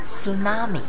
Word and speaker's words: tsunami: Maybe you guys don't think it's very hard because, for example, I said tsunami, T 不发音，tsunami，tsunami 不好拼哦，背一下，T S U N A M I。